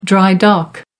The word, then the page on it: dry dock: American English